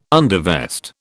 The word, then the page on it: undervest: British English